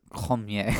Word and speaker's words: Cormier: No, it's Cormier.